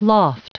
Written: loft

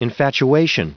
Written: infatuation

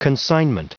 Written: consignment

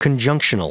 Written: conjunctional

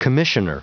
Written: commissioner

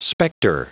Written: specter

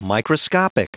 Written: microscopic

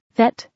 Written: vet

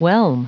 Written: whelm

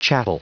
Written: chattel

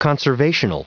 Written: conservational

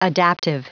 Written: adaptive

